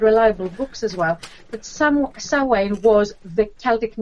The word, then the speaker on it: Samhain: I know it's spelled with an M, but it's Samhain, just to confuse everyone.